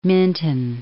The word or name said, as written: Minton